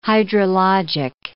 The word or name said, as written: hydrologic